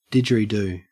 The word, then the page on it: didgeridoo: Australian English